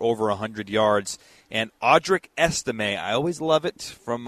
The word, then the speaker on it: Estime: When I see a name with a little asterisk over the E, little French lesson for everybody that has an A sound at the end. So Audric Estime has been averaging six yards per carry over the past two games 35 times, 210 yards, three touchdowns.